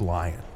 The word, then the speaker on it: Lyonne: It's pronounced lion. – There's a lion on your world that has powers? – Natasha Lyon. You said Lyonne.